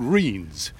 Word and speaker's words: rhyne: They're called reens, although it's spelled R-H-Y-N-E, and that's drained all the land.